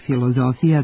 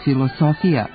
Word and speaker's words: filozofija: godine, kada je oblik filozofija bio naveden kao jedini ispravan, prema pravilu da se suglasnik s među samoglasnicima zamenjuje sa z i u grčkim i u latinskim rečima prema tradicionalnom izgovoru tog latinskog glasa.